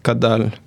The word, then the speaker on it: Kaddal: Same name for Twitter, Facebook, and my website, Khaled Kadal. – Khaled is spelled K-H-A-L-E-D-K-A-D-D-A-L.